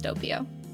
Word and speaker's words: doppio: It's literally just doppio.